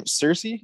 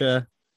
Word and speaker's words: Saoirse: What's her name? Cersei? Is that how you pronounce Saoirse? – It? – Saoirse. Saoirse, I believe it is.